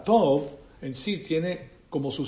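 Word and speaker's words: tov (טוב): Porque resulta que hay personas que dicen que eso se trata, ese bueno, que es la palabra tov, así se pronuncia en el hebreo, es T-O-B, tov.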